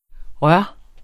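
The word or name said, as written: rør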